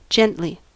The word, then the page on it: gently: American English